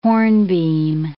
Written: hornbeam

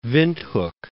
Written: Windhoek

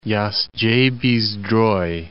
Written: Jastrzebie-Zdroj

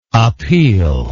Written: appealer

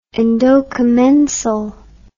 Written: endocommensal

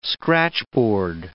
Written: scratchboard

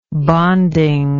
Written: bonding

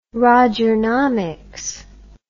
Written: rogernomics

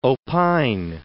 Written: opine

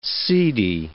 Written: seedy

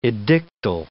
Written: edictal

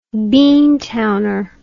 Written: beantowner

